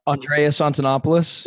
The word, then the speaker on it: Andreas Antonopoulos: Andreas Antonopoulos.